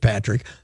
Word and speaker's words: Patrik: I, I, have no problem with it, especially since he pronounces it Patrick.